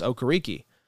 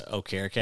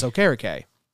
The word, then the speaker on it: Okereke: Well, they also mispronounce Bobby Okereke. Now, to be fair, he never corrected. That was what they used to say was Okereke. – And he said it's Okereke. – It's Okereke. But he never corrected them, though.